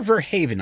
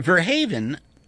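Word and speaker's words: Verhoeven: I mean, Peter Verhaeven, I believe is the correct pronunciation of his, his name is Dutch filmmaker.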